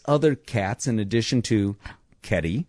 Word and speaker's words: Kedi: Kitty? – That's a word. – Well, no, it's spelled K-E-D-I. – Oh, Keddie, all right.